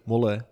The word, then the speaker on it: Molle: En eh, we wisten natuurlijk niet helemaal hoe de uitspraak was. Molle, Molle. Maar het is dus echt Molle. Ja, toch wel.